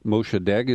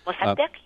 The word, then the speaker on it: Mossadegh: Mossadegh is how you would pronounce it in Farsi, I guess. – Mossadegh, yes.